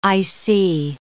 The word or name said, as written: IC